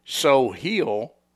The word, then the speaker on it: Soheil: And and his name is So Hill, but it's spelled S O H E I L.